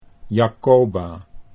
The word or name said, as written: Jacoba